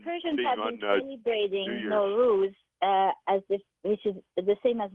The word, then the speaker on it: Nowruz: and it's spelled here as Nowruz, like Nowruz, and it's the first day of spring every year.